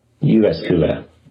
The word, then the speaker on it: Jyväskylä: Jyväskylä. – University of Jyväskylä, maybe.